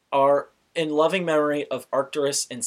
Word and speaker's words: Arcturus: I was just going to say Arcturus. It's like Arctic, but instead of the end of it, it's Urus.